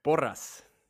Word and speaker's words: Porras: Porras. – Okay, so you do roll the R.